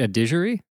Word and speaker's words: Adigéry: But do you have any idea of how to pronounce her last name before I try? – Charlotte Adigéry. – That's how I was going to do it.